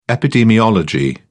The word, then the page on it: epidemiology: British English